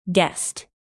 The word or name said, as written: guest